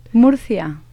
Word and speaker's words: Murcia: In Murcia? I'm not sure if I'm saying it right. – Murcia. – That's probably more likely how to say it.